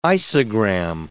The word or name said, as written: isogram